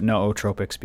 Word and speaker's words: nootropics: Now I'm going to pronounce it nootropics because my good friend Dr. Dan Engel said it that way.